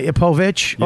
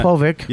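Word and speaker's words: Povich: Oh my, Povic or Povic, whichever you choose. – That's how you pronounce it in Polish.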